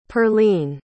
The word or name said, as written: pearlene